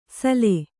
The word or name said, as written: ಸಲೆ